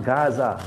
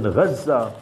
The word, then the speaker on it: Gaza: If you go to Gaza today, it's not Gaza. Learn the Islamic pronunciation, Gaza.